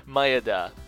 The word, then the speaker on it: Maeda: Maeda. M-A-E-D-A.